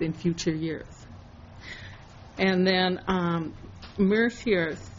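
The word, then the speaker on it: Mercer: One was hired by, I believe, MSRS Mercier. Did I pronounce them right? – Yes, Mercier.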